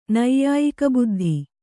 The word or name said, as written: ನೈಯಾಯಿಕಬುದ್ಧಿ